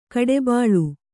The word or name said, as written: ಕಡುಬಲಿ